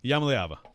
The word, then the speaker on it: Iamaleava: How does Nico Iamaleava, I'm going to butcher that all year long. – You got this. – I got nailed it the second time, though. – Iamaleava.